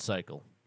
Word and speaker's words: Psycle: Before we move into our weeks, check out our friends in Cycle. – Yes. – Oh, God. Oh, P-S-Y-C-L-E. That's how they spell it.